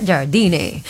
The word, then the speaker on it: Jardine: André Jardine, así se pronuncia, Jardine, informan que deja el Club América, era el director técnico.